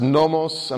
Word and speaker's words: nomos: That would be, no, that's the Greek version of it, and it's pronounced nomos, and I've got it in the transliteration.